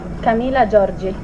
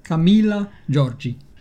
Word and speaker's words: Camilla Giorgi: It's for our media guide. So the broadcasters, when they want to know how to correctly say your name. – Oh, okay, perfecto. Camilla Giorgi.